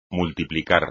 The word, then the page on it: multiplicar: espagnol